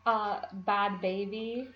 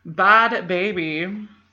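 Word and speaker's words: Bhad Bhabie: Danielle Bergoli, also known as the Cash Me Outside girl, and Behad Behaby. How is it pronounced? – Bad baby. – Bad baby.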